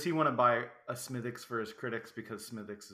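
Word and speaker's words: Smithwick's: Some people pronounce it Smithwix, but it's Smithix.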